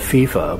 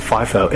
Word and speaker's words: FIFO: I call it FIFO, but some people might pronounce it FIFO, F-I-F-O.